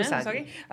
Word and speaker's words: Usagi: Usagi, am I saying that right? Yeah, Usagi.